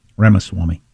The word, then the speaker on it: Ramaswamy: Is Vivek Ramaswamy a fajita or a taco? Ramaswamy. I left out a syllable.